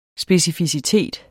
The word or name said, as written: SPECIFICITET